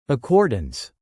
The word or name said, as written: accordance